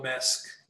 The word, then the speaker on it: Maersk: It's AP Moller Maersk.